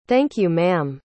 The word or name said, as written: thank-you-ma'am